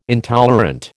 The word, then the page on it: intolerant: American English